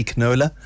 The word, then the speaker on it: Knola: So it's Wendy Knola, consumer. – The K is silent.